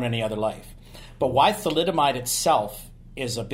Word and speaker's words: thalidomide: Thalidomide. – Thalidomide.